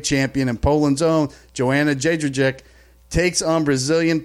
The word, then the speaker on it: Jędrzejczyk: Jędrzejczyk.